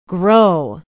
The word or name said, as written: grow